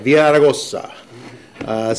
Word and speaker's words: Villaraigosa: Conference of Mayors, and really excited about the opportunity to serve with our president in the coming year, Mayor Antonio Villaraigosa, who likes me because he says I'm the only one that actually pronounces his name. Right. Everyone else butchers it, and I go, Villaraigosa.